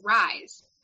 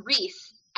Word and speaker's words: Rhys: They don't have titles or covers yet or publication dates, but I'm just like, so anyway, one of the characters, it's spelled R-H-Y-S. So when I was reading it, because I didn't listen to the audio, I read that as Rise. That's how I read it. Well, then I saw something on Instagram, a recording of her talking, and it's pronounced Reese.